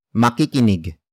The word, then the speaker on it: makikinig: The first word is makikinig. Makikinig. Makikinig. Makikinig is spelled as M-A-K-I-K-I-N-I-G.